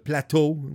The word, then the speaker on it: plateau: The plateau, like he said it. That's how you're supposed to pronounce it in French.